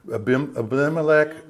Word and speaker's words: Abimelech: Be careful with, is it Abimelech? Am I saying that right? Yeah, Abimelech.